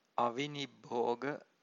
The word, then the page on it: avinibbhoga: Pali